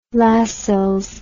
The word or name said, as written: Lascelles